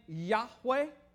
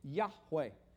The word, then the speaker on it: Yahweh: have you ever heard the name Yahweh? Okay, that's where it comes from. Yud-Heh-Wah-Heh. You can kind of hear that, can't you? Yud-Heh-Wah-Heh, Yahweh.